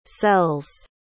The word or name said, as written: cells